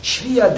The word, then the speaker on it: שפיר: ובדיני השפיר, שפיר, the right pronunciation is שפיר, שפיר that's the membranous sac in which the fetus is developed.